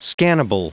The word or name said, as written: scannable